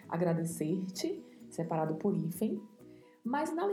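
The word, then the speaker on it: agradecerte: Bastante atenção com a pronúncia, porque temos o vibrato da letra R e temos o T ao final, que não é pronunciado ti, é pronunciado T. Então, agradecer-te por,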